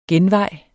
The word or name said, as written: genvej